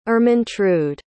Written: Ermintrude

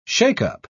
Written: shake-up

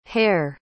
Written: herr

